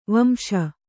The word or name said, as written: ವಾಂಶ